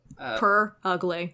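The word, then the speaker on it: Purugly: Purrugly. – Yeah, oh, okay. P-U-R-R ugly. – Literally what it sounds like. – Like coyote ugly, but with purr instead of coyote.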